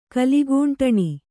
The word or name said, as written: ಕಲಿಗೂಂಟಣಿ